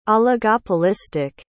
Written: oligopolistic

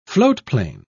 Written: floatplane

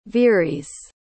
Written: veeries

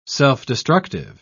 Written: self-destructive

